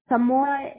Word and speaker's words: Zamora: Yes, Zamora is a Z as in Zebra, A M O Z-A-M-O-R-A. – Z-A-M-O-R-A.